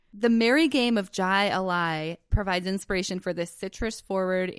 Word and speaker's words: Jai Alai: Jai Alai. And also should be Googling the pronunciation to correct me, please. – I think the J is silent.